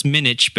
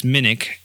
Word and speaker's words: Minick: Minick, which I thought was Minich, but then I got schooled. It's Minick, like the hard pronunciation there.